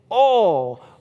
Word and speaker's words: oil: I am absolutely pronouncing this word the correct way. It is only one syllable. It is oil.